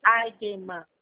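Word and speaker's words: Eigenmann: Se escreve como Eijeman, mas se pronuncia Eijeman, tá? – Soletra para mim, por Eijeman.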